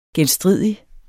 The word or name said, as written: genstridig